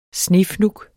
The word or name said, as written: snefnug